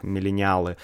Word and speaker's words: миллениалы: Поколение Y — это миллениалы, в русском языке это произносится так, миллениалы.